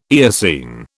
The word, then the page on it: Eocene: British English